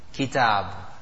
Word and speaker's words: Kitab: That's Kaf. Kitab. Easy pronounced.